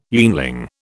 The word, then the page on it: yeanling: British English